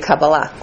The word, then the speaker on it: Kabbalah: That's the Ashkenazic pronunciation. It should be Kabbalah. In modern Hebrew or in Sephardic pronounced Hebrew.